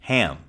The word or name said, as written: ham